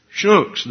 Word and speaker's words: Schnucks: Is it Schnucks? Schnucks. There's an N.